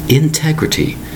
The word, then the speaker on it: integrity: Integrity. Four distinct syllables. Spelled I-N-T-E-G-R-I-T-Y. Integrity. Remember, four distinct syllables.